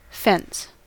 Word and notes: fence: American English

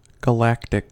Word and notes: galactic: American English

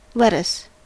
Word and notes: lettuce: American English

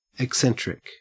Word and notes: eccentric: Australian English

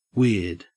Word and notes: weird: Australian English

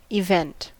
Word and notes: event: American English

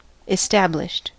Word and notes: established: American English

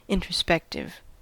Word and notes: introspective: American English

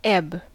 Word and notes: ebb: American English

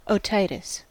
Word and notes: otitis: American English

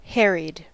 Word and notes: harried: American English

